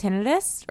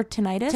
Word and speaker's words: tinnitus: Do you ever see people with tinnitus or tinnitus? – Tinnitus. – It could be pronounced either way.